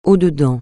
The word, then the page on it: au-dedans: francais